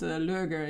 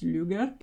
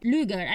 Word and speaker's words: Lueger: So it's not Lueger, it's Lueger. – Lueger. I think it would be Lueger because it's the UE thing.